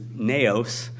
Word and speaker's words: naos: The Greek word, as I've mentioned before here, that's translated temple is naos, and it's spelled N-A-O-S, naos, and it literally means the sanctuary.